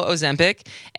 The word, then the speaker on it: Ozempic: Ozempic, and Wegovy.